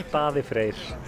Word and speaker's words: Daði Freyr: Daði Freyr.